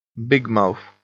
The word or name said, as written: bigmouth